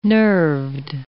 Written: nerved

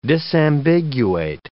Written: disambiguate

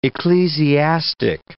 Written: ecclesiastic

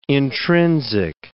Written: intrinsic

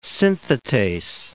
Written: synthetase

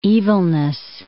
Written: evilness

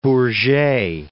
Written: Bourget

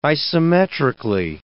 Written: isometrically

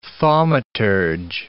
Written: thaumaturge

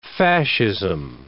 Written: fascism

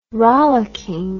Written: rollocking

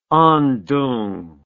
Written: Andong

